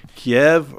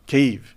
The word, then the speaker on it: Kyiv: We've been hearing about the capital city and a discussion of the proper pronunciation, whether it should be Kiev or Kyiv. – Kyiv. Yeah. – All right. And so, and is that significant that we get that right? – Well, it's, you know, if you use a Texas twang, it's okay. You know, whether the pronunciation is for Ukrainians, Kiev is the pronunciation. That's how I pronounce it.